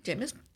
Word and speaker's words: Jeymes: Oh, how do you say the first name? Oh, yeah. Yeah, like James. – Okay, so Jameis Samuel is spelled J-E-Y-M-E-S. – Okay, that sounds about right. Okay. – Jameis.